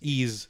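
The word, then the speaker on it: Ys: Yeah, so, the game is spelled Y-S. – Yes, Ease. – Yeah. – Not Y's. So yeah, basically that. And definitely not Y apostrophe.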